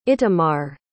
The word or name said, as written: itamar